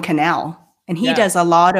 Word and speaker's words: Cannell: I'm going to tell you how I remembered that is because he told me Cannell rhymes with channel, and so now I never have forgotten it.